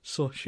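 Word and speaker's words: Xochitl: It's Sochi.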